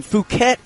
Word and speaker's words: Phuket: And Phuket is the number one tourist attraction. – You're saying that right. – I am.